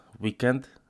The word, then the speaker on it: WKND: Gdzieś czytałem, że to się wymawia weekend. Że to jest WKND Skateboards. To jest skrót od weekend.